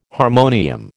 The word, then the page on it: harmonium: American English